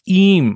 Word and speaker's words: Eyam: E-Y-A-M. – So, like, if English made sense, right, you'll be E-M, but yeah. But no, it's E-M, apparently.